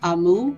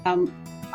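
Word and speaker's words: Amaou (あまおう): It says fluffy sponge cake with whipped cream and amu strawberries. What are amu? Is that how you pronounce it? – Amaou. Amaou. That pronounced as Amaou. Amaou. Amaou. Amaou is very difficult pronunciation, but that's very good strawberries and very popular Amaou.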